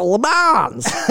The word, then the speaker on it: Le Mans: It's not Le Mans, because it's, I don't think, hey, I'm not French, but it's not Les Mans, and you don't pronounce the S at the end, it's Le Mans.